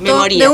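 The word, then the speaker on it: memoría: Memoria. – De un gesto, memoria. ¿Sabes que hoy estaba...? – Pero porque está escrito a propósito como unido, memoria, pero con acento en la I.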